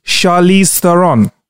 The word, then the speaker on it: Charlize Theron: Charlize Theron.